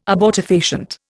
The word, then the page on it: abortifacient: Indian